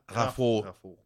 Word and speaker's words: Raffault: Olga Raffo.